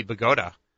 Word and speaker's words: Bogota: And now he faces little-known former mayor of Bogota, not Bogota, Colombia, or it could be Bogota, I think is the appropriate New Jersey pronunciation, Steve Lonigan.